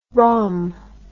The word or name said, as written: rhum